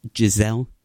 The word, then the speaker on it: Gisele: Is his wife named Gisele? – Stop it. So no, that's how you say it, right? – That's how you say it. Yes, very good.